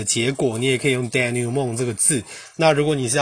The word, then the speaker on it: denouement: denouement，denouement，他念 d e n o u e，denou，d e n o u e，denouement，因为 m e n t，m e n t，denouement，它这个 t 是不发音的，所以 denouement，它就是指一般小说戏剧的收场，或是任何复杂情况的一种解套或解决方法。